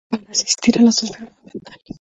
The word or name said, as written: asistir